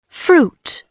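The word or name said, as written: fruit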